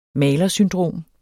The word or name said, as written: malersyndrom